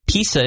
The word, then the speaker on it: PISA: It is, PISA is how you say it.